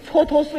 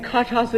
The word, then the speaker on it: 蹉跎: 这就是前些日子我看那个电视连续剧《蹉跎岁月》，我那个题目念错了。 – 哦，蹉跎俩字不认识？ – 我不认识。 – 你念成什么了？ – 我念成咔嚓岁月。